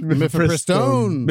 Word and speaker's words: Mifepristone: They will be deciding on the Mifepristone ruling from that Texas judge who says, what did I say it wrong? – Mifepristone. – Mifepristone. – That's a spicy Mifepristone. – Now I know how to say it.